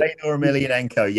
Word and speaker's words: Fedor Emelianenko: In a bid to make New Japan more legitimate in MMA, he fed Nagata to Mirko Krokop and Fedor Emelianenko. Is that how you pronounce it? – Fedor Emelianenko, yeah. Well, arguably one of the greatest MMA fighters of all time.